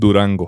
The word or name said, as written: Durango